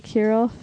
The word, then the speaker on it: Kirov: So anyways, German or not, Michael Frederick Kirov. Is that how you would pronounce that? – I would say Kirov, yeah.